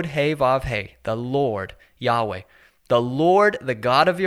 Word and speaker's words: Yahweh: We say Yahweh. It is only an approximate pronunciation because the original has been lost, but that is the name.